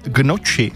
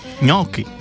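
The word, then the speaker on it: gnocchi: A taky Marťa, ahojda, tak mě tahá za uši, když někdo řekne, že by si dal gnoči, to správně je ňoky.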